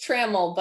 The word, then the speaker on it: Trammell: It's Trammell, but Trammell.